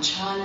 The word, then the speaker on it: Chan: Chan is the Chinese pronunciation of the word that's pronounced Zen in Japanese.